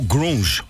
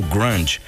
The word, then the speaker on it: grunge: o grunge ou grunge, como você queira pronunciar, mas a gente conheceu bandas incríveis, na verdade, por conta desse estilo.